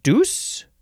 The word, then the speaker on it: douse: The third form of douse, uh, you can also pronounce it deuce.